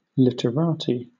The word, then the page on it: literati: British English